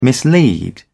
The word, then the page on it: mislead: British English